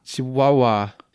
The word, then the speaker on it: Chihuahua: Chihuahua. It's like a wah-wah pedal.